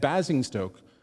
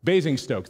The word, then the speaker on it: Basingstoke: Basingstoke." Did I say that right? No. Okay. Basingstoke. Thank you.